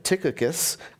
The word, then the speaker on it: Tychicus: Then he says, I sent his name is pronounced Tychicus.